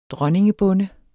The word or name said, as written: dronningebonde